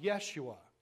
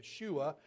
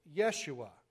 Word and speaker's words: Yeshua: But it is pronounced Yeshua. Some will put the wrong emphasis on the wrong syllable and say Yeshua. It's Yeshua.